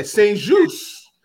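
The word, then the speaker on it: St-Juste: Yeah, Saint-Juste. Yeah, I think it's French.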